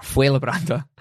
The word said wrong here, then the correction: Fuenlabrada: I've been putting an N in where there wasn't an N. so Fuen Fuen Labrada um, have won.